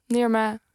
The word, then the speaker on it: Nirma: Gemaakt door Nirma Hagenburg. Zeg ik dat goed? – Nirma, ja, in ieder geval. – Nirma heb ik goed gezegd.